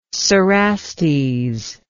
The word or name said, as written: cerastes